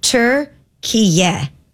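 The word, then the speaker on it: Türkiye: The official pronunciation of the country's recognized name is Turkiye.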